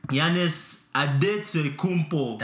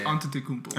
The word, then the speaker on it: Antetokounmpo: That's right. That's how you say it. – Have I been saying it wrong this whole time? – I mean, not really. – That's the Nigerian way. Because I say Antetokounmpo. – That's the Greek way to say it.